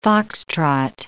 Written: fox-trot

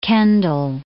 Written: Kendall

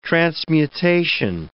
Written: transmutation

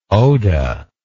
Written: oda

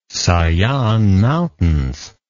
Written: sayan mountains

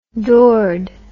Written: doored

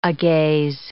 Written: agaze

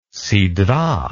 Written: sidrah

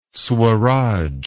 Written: swaraj

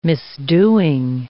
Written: misdoing

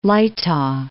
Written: Leitha

